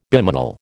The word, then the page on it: bimanal: American English